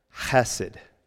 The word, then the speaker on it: chesed: It's a Hebrew word that you would pronounce chesed. Chesed.